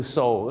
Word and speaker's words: sew: ตัว EW ถ้าเป็น SEW ก็คือ so